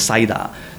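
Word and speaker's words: saida (サイダー): The phonetic pronunciation is, in Japanese, is saida, so it sounds the same as it's spelled.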